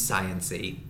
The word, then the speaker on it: science-y: That seems science-y. – Yeah. No, that's science-y. – Fair. – Science-y. The last syllable is very important.